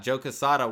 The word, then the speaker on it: Quesada: He was at his table, but it was weird because he was at his table that Saturday at the same time Joe Quesada, is that how you say his name? Yeah. Joe Quesada was doing a signing on the other side of the convention.